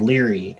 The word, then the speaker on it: Lire: I think, if I remember correctly, there's an RSS app called Leary, and getting Siri to pronounce it, because it's written L-I-R-E, getting Siri to recognize it is very difficult.